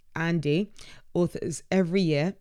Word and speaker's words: Ahndy: So yeah, again, Elizabeth Marsden go, yes, I'm going to shamelessly promote our benchmark report that Wizard pronounced Andy, that's the handle, pronounced Andy, so A-H-N-D-Y, authors every quarter.